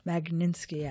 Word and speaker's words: Magnitsky: There's the Uyghur Human Rights Project, and they're trying to pass something called the, I'm going to say it wrong, Meninsky Act. I know I'm saying it wrong. They're trying to pass legislation. It's very tricky. – Wait. – Magnitsky Act.